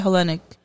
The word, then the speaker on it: Hellenic: Say the Hellenic. – Yeah. – Am I pronouncing that? – You are. – Cause it's like Helena, right? – Yep.